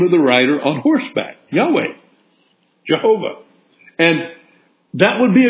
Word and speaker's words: Yahweh: T-O-N. They would not profane that name with human pronunciation, so they used this four-letter form and pronounced it Yahweh, Yahweh.